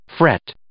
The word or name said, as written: fret